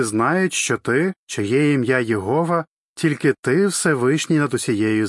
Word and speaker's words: Єгова: Українською це ім'я зазвичай вимовляється Єгова. Дехто вимовляє його як Яхве.